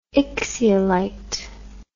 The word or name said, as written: ixiolite